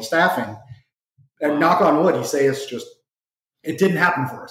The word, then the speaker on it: Isaias: I mean, Isaias, if I pronounced that correctly. – That's right.